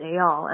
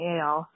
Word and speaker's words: Eyal: Anyway, the other thing I wanted to say is that it's, his name, Eyal, is pronounced Eyal, and I know that because it was a huge plot line on, like, the whole first week of that season of Love Island, because at first he was paired with that girl who you probably remember didn't know what Brexit was, so she was kind of, like, impossibly ignorant, and she had a huge problem pronouncing Eyal, so we got a lot of reminders. It's pronounced Eyal.